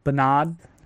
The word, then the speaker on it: Bernard: Our favorite L in the world suggested Bernard, but pronounced like the British way, what is that? Bernard?